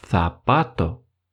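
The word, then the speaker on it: zapato: Когда мы видим эту букву, без всяких исключений, мы произносим ее как шепелявое «с». Например, башмак, ботинок будет называться «сапато».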